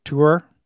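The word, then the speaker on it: Týr: It's a Norse god. So it would be with a front rounded vowel like a German umlaut. So Tur.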